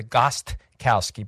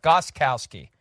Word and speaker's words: Gostkowski: A lot of people say the Gostkowski, but I think the T is silent. Gostkowski.